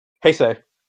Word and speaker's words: Heisei: Is it pronounced Heisei? – Heisei. – Heisei? – Yeah.